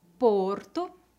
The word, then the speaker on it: porto: Muitas palavras têm a pronúncia fechada no singular e aberta no plural. De novo, isso não é uma regra, mas acontece com muitas palavras. Vamos ver alguns exemplos. Aeroporto, aeroportos. Porto, portos. Povo, povos.